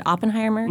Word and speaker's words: Oppenheimer: Robert, you said Oppenheimer? – Mm-hmm. – That's how you say it? – Yep.